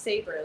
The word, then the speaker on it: Saber: but my name is pronounced Saber, like a saber-toothed tiger or a saber sword.